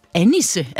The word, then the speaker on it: Annisse: Du er sognepræst i Folkekirken Ramløse og Annisse. Er det sådan, man udtaler det? – Ja, sådan man udtaler det.